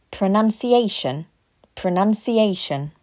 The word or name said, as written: Pronunciation